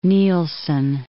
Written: Nielsen

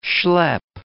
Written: schlep

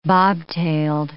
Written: bobtailed

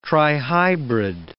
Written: trihybrid

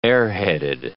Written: airheaded

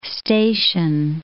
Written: station

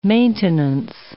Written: maintenance

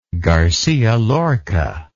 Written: garcia lorca

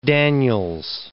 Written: Daniels

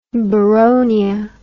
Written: boronia

